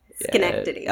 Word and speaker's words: Schenectady: Schenectady.